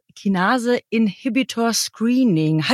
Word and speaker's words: Kinase-Inhibitor-Screening: Und ein zentraler Begriff, den du dort genannt hast, war das Kinase-Inhibitor-Screening.